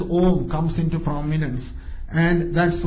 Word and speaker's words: Om: So here, this letter Om, as I mentioned before, it has got three parts, you say, A, U and Ma. A, U and Ma. So, when you pronounce the letter Om, that is, these three letters are combined together. Om.